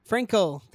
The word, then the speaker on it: Frankl: Victor Frankie, is that correct? – Frankl. – Frankl, thank you, Victor, yeah, Viktor Frankl.